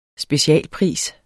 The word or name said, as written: specialpris